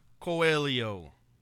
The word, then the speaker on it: Coelho: Our last name is a Portuguese word, and you look at the age, and it's pronounced like a Y. So you pronounce every vowel, but then the age is like a Y. So it's pronounced Coelho.